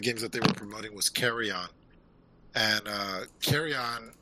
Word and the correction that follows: Carrion: am I saying that right? Carrion. Carrion.